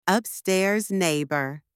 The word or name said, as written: upstairs neighbour